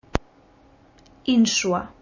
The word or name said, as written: Insua